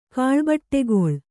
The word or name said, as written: ಕಾಳ್ಬಟ್ಟೆಗೊಳ್